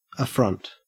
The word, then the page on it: affront: Australian English